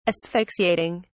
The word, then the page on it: asphyxiating: British English